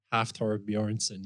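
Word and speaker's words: Hafþór Björnsson: Half Thor Bjornsson, am I right? – Half Thor Bjornsson, yeah.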